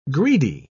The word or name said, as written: greedy